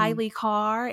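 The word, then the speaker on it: Kailei Carr: Kylie Carr.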